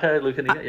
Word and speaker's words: Lucanica: I was just going to give you a shout out on your Greek heritage, because I know the Lucanica. – Yeah. – Did I say that right? Correctly? – Yeah, exactly. Lucanica.